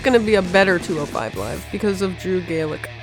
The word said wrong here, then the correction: Gulak: Gulak. Gulak. – Gulak. I went on his Twitter and it says it's pronounced like attack.